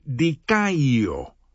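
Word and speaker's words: dikaio (δικαιόω): Pues bien, la palabra griega para justificar suena y se escribe de esta forma, dikaio, mientras que la palabra para justicia es muy similar, dikaiosune.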